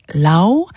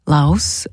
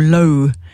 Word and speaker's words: Laos: Susan said, you pronounce this country, Laos, without the S. So it's got a silent S. I either Lao or Lo.